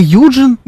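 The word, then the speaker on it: Eugene: Евгений — это Юджин? – Да. Там... Он даже так и пишется. Е-у-ге-не.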